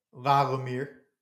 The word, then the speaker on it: La Lumiere: How do you say that school? – La Lumiere. – I always say La Lumiere, like Beauty and the Beast.